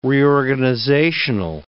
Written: reorganizational

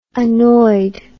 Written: annoyed

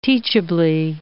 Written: teachably

